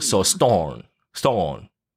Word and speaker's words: storm: OK, and the storm, you know, the M is lost, storm. So storm. A lot of people pronounce it like that, so remember the last syllable.